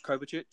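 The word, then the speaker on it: Kovačić: We've just recently this evening got Kovacic. Is it Kovacic? Is that pronounced right? – Kovacic. You're referring to the loan deal to Chelsea.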